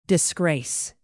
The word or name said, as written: disgrace